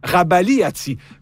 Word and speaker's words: Rabagliati: L'auteur des bandes dessinées de la série Paul, Michel Rabagliati, aussi prononcé Rabagliati, fait son entrée dans le Robert illustré 2027.